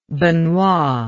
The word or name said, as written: Benoit